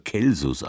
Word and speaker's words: Celsus: Lateinisch Celsus, früher Celsus ausgesprochen, heißt hervorragend, erhaben.